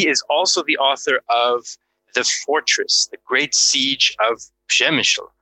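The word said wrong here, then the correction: Przemyśl: And I mispronounced that right off the start, folks. It's Przemysl. The L is somehow silent, but maybe we'll solve that mystery in the course of the podcast.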